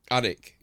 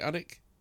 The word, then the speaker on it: Alnwick: How do you pronounce that? I don't know how to pronounce that. – Alnwick. – Northumberland. Annick. Is it Annick? I think it's Annick, isn't it? Northumberland.